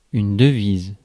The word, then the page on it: currency: American English; /ˈkɝ.ən.si/